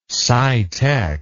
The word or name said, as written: scitech